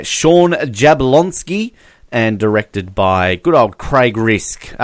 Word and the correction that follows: Zisk: uh, Craig Zisk. Sorry, I can't even pronounce his name because it's a different letter than I went for.